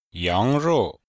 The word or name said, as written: yang2rou4